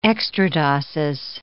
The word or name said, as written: extradoses